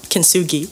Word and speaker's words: Kintsugi: Now what the on the cover art it says, I'm probably gonna butcher this, but Kintsugi memoir. – Kintsugi.